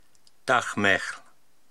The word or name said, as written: Dahmehl